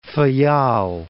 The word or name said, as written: Faial